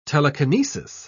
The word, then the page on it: telekinesis: British English